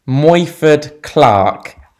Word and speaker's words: Morfydd Clark: Okay, it's the Welsh actress Morfydd Clark.